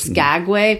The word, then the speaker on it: Skagway: Skagway? – Mm-hmm.